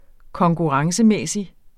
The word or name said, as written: konkurrencemæssig